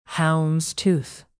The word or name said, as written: houndstooth